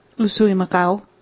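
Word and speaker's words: Usui Mikao: How would you say it? – Usui Makao. – There you go.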